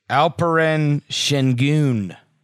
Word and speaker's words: Alperen Şengün: The Turkish Alperen Şengün.